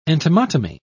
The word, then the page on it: entomotomy: British English